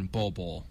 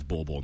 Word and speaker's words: Bol Bol: If you're talking about two big guys, I think Taco Fall had more upside than Bol Bol. – I'm sorry, it's Bol Bol, not Bol Bol.